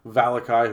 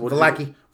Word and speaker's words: Vallaki: Barovia or, or Valakai, whoever you want to pronounce it. – Vallaki.